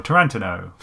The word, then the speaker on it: Tarantino: Because on the one hand, we could just stop there and say Tarantino or Tarantino, as is correctly pronounced.